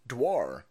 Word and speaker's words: Dior: Dior is usually pronounced Dwar. Dwar is French.